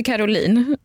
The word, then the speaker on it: Caroline: För att du, om vi kommer in på Caroline, det stavs ju Caroline, men i första boken får man veta att det är Caroline det uttalas.